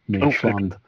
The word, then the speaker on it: Midtjylland: I think even Midtjylland, apparently, according to Neil Atkinson, that's how that's pronounced, Midtjylland.